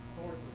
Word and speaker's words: Thornton: Okay, let me spell it. THOORENTON. How do you say that? – Thornton.